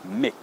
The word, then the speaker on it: Mc: Now, a lot of people often think it is actually Mac because of maybe Big Mac, or in Japanese it's Makku. But actually, in English, MC is more of a Mick, not Mac.